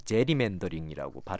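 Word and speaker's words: gerrymandering: 이런 게 이제 게리맨더링인데 미국 사람들은 보통 제리맨더링이라고 발음합니다.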